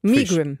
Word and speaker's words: Megrim: Habe ich es jetzt schon mal richtig ausgesprochen? – Ja? Ich gehe davon. Megrim. – Megrim.